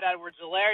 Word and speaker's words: Edwards-Helaire: I know the H is silent, but it still feels smooth. It's just right. – Yeah, you can go by Clyde. You can go by Clyde Edwards-Helaire. You can go with Clyde the Glide, C-E-H.